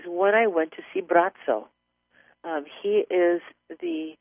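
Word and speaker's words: Braco: You can find, just if you Google Brazzo, but his name is spelled B as in boy, R-A-C-O, and then put a dash Cleveland.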